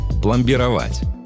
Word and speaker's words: пломбировать: Одно из них – пломбировать. Да, ударение в нем ставится именно так и никак иначе. Только такой вариант разрешает словари.